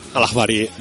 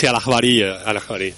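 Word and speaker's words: Al-Ikhbariya: Según documentos citados por Wikileaks, citados el jueves pasado por el canal estatal sirio Al-Ikbarilla, Obama... – Al-Akhbari. – ¿Me lo pronuncias tú bien? – Bueno, en dialecto costeño, digamos, sí, Al-Akhbari.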